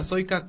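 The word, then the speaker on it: Sojka: Casa Cultural Trude Soika, Soika con J en lugar de la I, como se pronuncia, y ahí tendremos la información actualizada sobre las fechas y pormenores de la inauguración.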